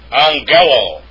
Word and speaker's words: angelo: Next form is angelo. And actually, when two gammas are put together, you get an N sound. And that's how it's pronounced.